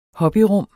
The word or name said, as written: hobbyrum